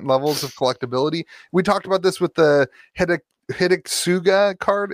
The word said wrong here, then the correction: Hidetsugu: Is that how you pronounce it? Hidetsugu.